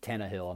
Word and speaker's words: Tannehill: You know, Ryan Tannehill could get hurt. Or Tannehill, I'm sorry, I say his name wrong.